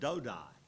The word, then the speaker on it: Dodai: Now, I know it says Dodo, but I'm saying Dodai because it sounds a little more reverent in English. And I'm not going to pronounce it like the Israelis pronounce it. That would be even worse.